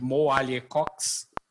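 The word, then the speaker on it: Mo Alie-Cox: Ele tem um novo alvo preferido, que é o Tyrande Mo Ali Cox. Falei certo? Falei, né? Falou, falou, falou certo.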